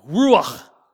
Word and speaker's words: ruach: You say that with me, ruach. And you have to, the if you haven't cleared your throat this morning, that's perfect. Ruach is the word. It's the way it's pronounced.